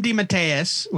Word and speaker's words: DeMatteis: J.M. DeMatteis, which is how you say his name, DeMatteis.